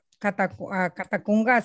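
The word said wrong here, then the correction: Latacunga: Lo digo bien, Catacunga City. – La Tacunga. sí.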